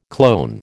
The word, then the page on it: clone: American English